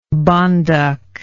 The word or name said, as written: bonduc